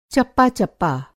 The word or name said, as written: चप्पा-चप्पा